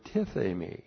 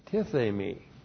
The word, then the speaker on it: tithemi: So whom, the Lord Jesus Christ, and then we have the verb tithemi. T-I-T-H, long E-M-I, tithemi.